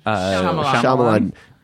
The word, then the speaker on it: Shyamalan: Night Shyamalan, how do you pronounce his name? – Shyamalan.